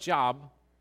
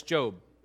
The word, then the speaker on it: Job: It looks like Job, but it's Job, okay? It's funny when people that haven't grown up in a church background, I say, open the book of Job. Well, how do you spell that? Is that J-O-B-E? No, it's spelled like Job. Well, why don't you say Job? Because historically, that's not how you pronounce Job.